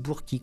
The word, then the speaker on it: Burke: Celui-ci s'appelle « L'arc-en-ciel de verre », du verre comme du verre, V-E-R-R-E. Il est signé James Lee Burkey, j'imagine qu'on doit prononcer comme ça. Moi je dirais Burke, ça, Burke, ça s'écrit B-U-R-K-E.